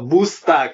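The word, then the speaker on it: Boosta: Come mai questo busta, che non è con la U ma con la doppia O e pronuncia anglosassone?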